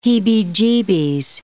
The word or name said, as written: heebie-jeebies